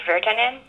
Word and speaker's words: Virtanen: I'm Hillary Virtanen, that's V-I-R-T-A-N-E-N, and right now I'm at my brand new office at Mannerheim Hall on the campus of Finlandia University in Hancock, Michigan.